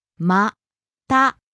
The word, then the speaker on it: また: また, また. – Rising on the last syllable, ta.